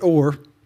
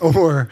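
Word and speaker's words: Hor: That it does have an H, but you pronounce it Or, just to keep everybody from getting in the wrong place. They traveled to Mount Or.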